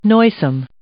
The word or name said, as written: noisome